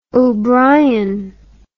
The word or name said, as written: obrien